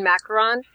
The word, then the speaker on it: macaron: Somebody posted a darn picture on Facebook of that lemon macaron, and I discovered at Jolly Holiday that I really do like macarons. Is that the right way to pronounce it? – Macaron. – Okay, thank you.